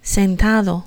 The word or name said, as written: sentado